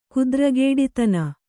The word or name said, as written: ಕುದ್ರಗೇಡಿತನ